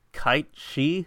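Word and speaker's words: Cait Sith: You know that Kate Sith is actually supposed to be pronounced like Kite She? – Yeah, it's.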